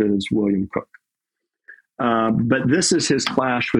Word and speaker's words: Coke: I have always heard that it is pronounced Cook, even though it is spelled Coke. And I agree with you. Look at C-O-K-E. I think that I should be pronouncing it Coke.